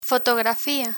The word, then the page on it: fotografía: Spanish